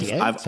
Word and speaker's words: Sartre: Is it pronounced Sartre? – I've Sartre.